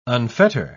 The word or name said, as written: unfetter